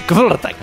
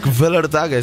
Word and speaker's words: Kvelertak: Vellertak, és que no sé com pronunciar una K i una P baixa de seguida, saps? Kvelertak. – Kvelertak, és que és així, aquests noruecs no fotem.